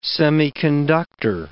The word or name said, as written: semiconductor